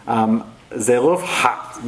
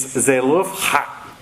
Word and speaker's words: Zelophehad: So it's pronounced Zelophehad. You have to do that ha thing at the end. Doesn't come out naturally to me. So it's Zelophehad, however that would come out.